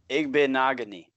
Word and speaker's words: Igbinoghene: Igbenogany.